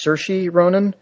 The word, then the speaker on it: Saoirse Ronan: It stars, I believe the correct pronunciation is Saoirse Ronan, young Irish lass who's been in a lot of movies lately.